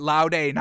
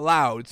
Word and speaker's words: laude: I think you pronounce it laude, not loud.